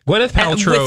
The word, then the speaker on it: Gwyneth Paltrow: Gwyneth Paltrow. – With an emphasis on the first syllable.